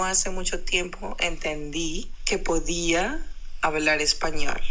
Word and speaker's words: podía: Podía, then it's podía. P-O. Podía.